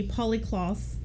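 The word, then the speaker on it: Polly Klaas: So on October 1st, 1993, Polly Kloss, is that how I say that correctly? – Yeah, okay, cool.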